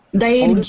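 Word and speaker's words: धैर्यम्: How do you pronounce? Dairyam or what is it? – Dairyam. – Dairyam.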